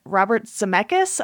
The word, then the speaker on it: Zemeckis: But this came out 1985, directed by Robert Zemeckis.